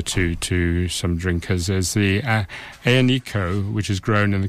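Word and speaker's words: Aglianico: So, as I say, it's pronounced Aionico. It's spelled Aglanico, A-G-L-A-N-I-C-O.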